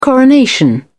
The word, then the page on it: coronation: British English